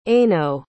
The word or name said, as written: eino